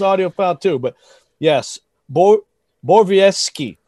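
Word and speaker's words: Borowiecki: See, if you had any guts, you would have gone with Mark Bovejecki, okay? That's and by the way, did I botch that pronunciation? – Yes, you did. It's Bovejecki.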